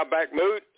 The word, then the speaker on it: Bakhmut: The Russians have encircled Bakhmut, I guess. Is that how you pronounce that city? – Yeah, Bakhmut.